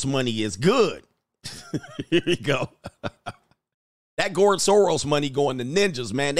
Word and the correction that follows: George Soros: Or George Soros. I keep saying it wrong. It's George Soros.